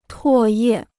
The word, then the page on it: 唾液: tuò yè